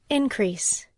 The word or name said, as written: increase